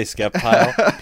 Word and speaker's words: Sceptile: Dude, people, if you say Sceptile, I grew up saying Sceptile, but like if you say Sceptile, people freak out. They're like, the sea is silent. I'm like, all right, Sceptile, sorry.